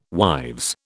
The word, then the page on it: wives: British English